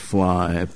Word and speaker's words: Phleigh: P-H-L-E-I-G-H, Fly? – Yeah, they're pronounced Fly, Pop Fly.